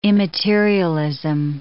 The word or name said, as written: immaterialism